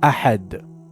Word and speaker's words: أحد: Correction: ensure proper articulation of Ahad with a correct guttural sound of Ha.